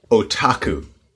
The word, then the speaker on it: otaku: Otaku.